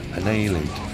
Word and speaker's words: Annihilator: Voy a decirlo, aunque sea una vez, de la pronunciación correcta, ¿te parece? – A ver. Annihilator.